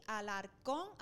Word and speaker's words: Alarcón: Monique se escribe M-O-N-I-Q-E u e, y Alarcón, así mismo como lo escuchan.